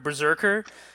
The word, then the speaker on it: BRSKR: Keanu Reeves, he's writing and releasing a comic called BRSKR, which, if you try to say the letters out loud, it sounds like the word berserker.